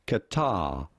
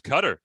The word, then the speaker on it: Qatar: How do you pronounce the name of this country? – Yeah. – Qatar. Qatar. – Qatar. – Oh, I thought people thought it was Cutter. That's what I'd heard some people say.